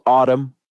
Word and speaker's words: Atum: It's called Autumn. It's spelled A T U M.